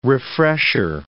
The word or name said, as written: refresher